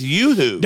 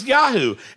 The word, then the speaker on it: Yoo-hoo: I'm like, it's Yoo-hoo. – Don't. – It is Yoo-hoo. – I'm like, it's Yoo-hoo. She's like, no, it's not. It's Yahoo. And I'm like, how do you spell it? Y-O-O-H-O-O. – Yeah. – Say Y-O-O-U.